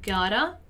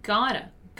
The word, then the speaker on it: got to: Once again, here, the pronunciation of got to, which is normally pronounced as gotta. All right, so gotta.